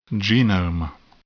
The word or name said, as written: genome